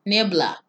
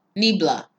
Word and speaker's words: Niebla: Nebia. – Nebia. – There's neva. – Cloud. It's spelled N-I-E-B. Oh, there's an L. Niebla. Niebla.